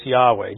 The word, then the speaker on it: Yahweh: Most recent scholarship and most people who really dig into the Hebrew language and try to reconstruct the pronunciation believe the correct pronunciation is Yahweh, just so you're aware of that.